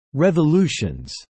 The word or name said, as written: revolutions